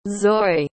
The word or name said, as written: zoi